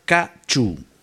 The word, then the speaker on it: Ca trù: Se llama Ca Chu. En verdad se escribe Ca-tru, pero se pronuncia Ca-chu.